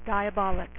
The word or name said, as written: Diablo